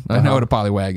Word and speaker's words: Poliwag: Poliwag. Now, if that I said wog. – Yeah, it's, you said it wrong.